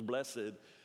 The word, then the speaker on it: blessed: And in the King James, blessed has two syllables, blessed.